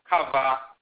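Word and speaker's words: Khavah: They have the consonantal structure, but they pronounce it Kava. And then in English, it becomes E, but it's Kava.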